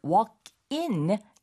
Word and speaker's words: walk in: 근데 빨리 발음하면 walking처럼 들릴 수 있어요. – 그럴 수 있어요. – 근데 walking이 네. – 아니고 네. walk in.